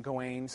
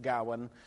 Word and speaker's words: Gawain: Uh, many people pronounce his name Gawain, some people pronounce it Gawain.